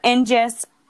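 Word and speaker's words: ignis: It is known for this name that the Latins use for fire, Angus. I think I'm saying this wrong. I think it's not Angus. I have to edit this. Okay. Fire, Ingus, and it is the root word of the English word that got the words ignite, ingenious, and ignition.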